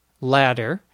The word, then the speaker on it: latter: So, for example, there's a word L-A-D-D-E-R, ladder, which you use to climb up to the top of your house. There's also the word L-A-T-T-E-R, which is also pronounced in normal conversation, ladder, meaning the later or the more recent.